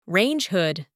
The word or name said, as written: range hood